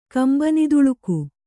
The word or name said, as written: ಕಂಬನಿದುಳುಕು